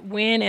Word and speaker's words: Wynne: I'm both on Instagram and Facebook at Wynn and Associates Counseling. And the last name is spelled W-Y-N-N-E.